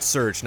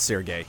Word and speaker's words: Serge: Serge is pronounced Serge, not Sergey. He's French. He's not Russian.